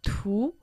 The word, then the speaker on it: 图: Слово «ту» произносится во втором доне.